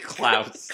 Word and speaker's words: Klaus: Santa Claus. How do you say it? Santa Claus. – Claus. – Santa Claus. – Claus. – Claus. K-L-A-U-S. Santa Claus.